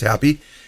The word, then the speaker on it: Hapi: H-A-P-I, but it's pronounced Happy.